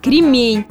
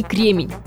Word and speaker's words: кремень: В слове «кремень» ударение должно ставиться только на второй слог, на вторую «е» – кремень, и уж никак не кремень. К слову, во всех словарях ударение на второй слог признается единственно верным.